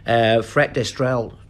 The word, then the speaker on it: Fret d'Estreville: Fret d'Estreville, I think that's how we're going to pronounce it. He's French. – Brilliant pronunciation there.